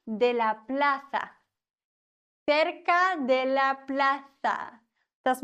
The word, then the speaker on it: plaza: En la plaza. Z wird gelispelt im Castellano.